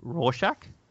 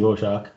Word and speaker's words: Rorschach: As many people say, I don't think that's actually how you say Rorschach. But yeah. – I could be wrong. – Pretty much how you say it, I think. I think there's a couple of ways to say it in English, but I think it's basically Rorschach or Rorschach.